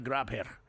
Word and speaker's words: Grabher: Julia Grabher. But it's pronounced Julia Graber.